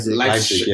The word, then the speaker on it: Leipzig: Leipzig, yeah. They are second with 13 points. – Yeah, so it's just a point difference. – Point difference, yeah. – I mean, when Leipzig, Timo Werner is a good player.